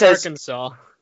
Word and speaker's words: Arkansas: I think it's pronounced Arkansas. – It is pronounced Arkansas. – It says yes. there's three syllables in the word, and you must pronounce it Arkansas.